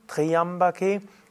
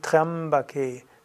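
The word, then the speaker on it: Tryambake: Es ist nicht Triambaka, Triambake, sondern Trambake. Das Y hier ist ein Konsonant.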